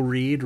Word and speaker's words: Reid: I am on Twitter at Joe Reed. Reed is spelled R-E-I-D. I am also on Letterboxd as Joe Reed. Reed is spelled R-E-I-D there as well.